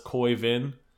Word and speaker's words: Khoi Vinh: Uh, it is a podcast uh, hosted by, I believe his name is pronounced Khoi Vinh. Yes.